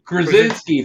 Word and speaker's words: Krasinski: Is it Kransky? Is that how you say it? – Krasinski. – Thank you. I can never say his name right.